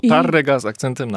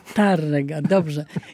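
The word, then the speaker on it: Tárrega: Tarrega z akcentem na pierwsze A. – Tarrega, dobrze.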